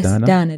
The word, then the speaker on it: Daneh: Yes, Dana. – But it's with an EH at the end. – Yes, Dana. – Dana. – Khaliji, Dana. – Khaliji. – Dana. Not Dana.